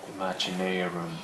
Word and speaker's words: Imaginaerum: The new album, how do you uh, how do you pronounce it now that you changed the name? – Imaginaerum. Imaginarium. Imaginarium.